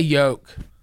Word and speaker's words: yolk: Oh, you do the L in yolk. – How do you say it? – I just said it. Yolk. It's like joke with a Y.